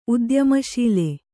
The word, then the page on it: ಉದ್ಯಮಶೀಲ: udyamaśila